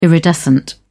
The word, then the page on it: iridescent: British English